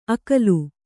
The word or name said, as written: ಅಕ್ಕುಲ